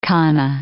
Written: kana

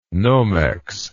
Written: nomex

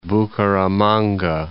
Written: Bucaramanga

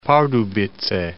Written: Pardubice

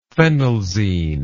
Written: phenelzine

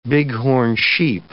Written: bighorn sheep

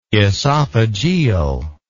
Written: oesophageal

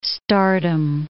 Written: stardom

